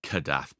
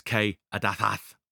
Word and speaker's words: Kadath: Some places pronounce it Kadath, some pronounce it Kadathath.